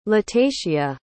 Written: latacia

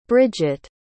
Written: bridgett

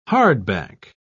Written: hardback

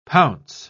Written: pounce

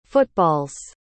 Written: football's

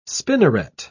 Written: spinneret

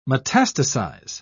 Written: metastasize

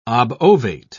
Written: obovate

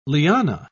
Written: liana

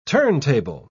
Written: turntable

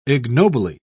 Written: ignobly

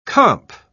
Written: comp

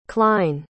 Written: Klein

